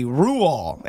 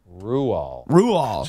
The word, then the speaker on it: Roald: I did a quick Google and it says the correct pronunciation of his name is actually Ruall, and the D at the end remains silent as the grave. I don't know what that means. – Ruall.